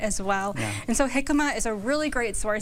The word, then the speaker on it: jicama: It's spelled J-I-C-A-M-A. Yes. What in the world is jicama? – Yes, so it's pronounced jicama because it comes from Mexico and Central America. – Oh, sure. – So okay, that makes sense. – Has the J is an H.